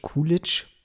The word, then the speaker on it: Coolidge: Uma Thurman, äh, Mia Wallace, Bruce Willis spielt Butch College. Äh, Butch, Butch, Butch Coolidge, Butch Coolidge, so spricht man es aus.